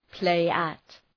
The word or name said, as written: play at